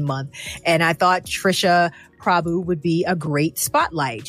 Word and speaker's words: Prabhu: Prabhu, yes.